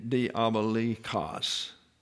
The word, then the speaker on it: diabolikos: Cosmos, world order, and the diabolikos. If you wanted to get the accent on the last syllable where it belongs, it means a slanderer, an accuser, and devilish.